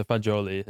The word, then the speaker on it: fagioli: So it might be fagioli, which is pasta with beans. – Is that how you say it? – Yeah, so fagioli is.